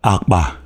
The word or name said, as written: Akpa